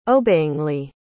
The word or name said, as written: obeyingly